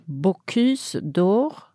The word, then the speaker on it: Bocuse d'Or: Bocuse d'Or. – Mm. Är det så man uttalar det? – Ja, det är väldigt korrekt var det faktiskt.